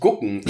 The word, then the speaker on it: gucken: Ja, das war, das als mir das erste Mal jemand gesagt hat, dass ich gucken äh, mit G ausspreche, was richtig ist. Übrigens.